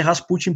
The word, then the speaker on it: Rasputin: Se fala Rasputin ou Rasputin, mas Uh, eu creio que a pronúncia correta é Rasputin, porque o acento é no, é no U, né?